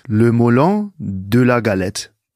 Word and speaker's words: Le Moulin de la Galette: Le Moulin de la Galette. – Oh gut. – Meinst du? – Ja, ich glaube, es muss genauso ausgesprochen werden.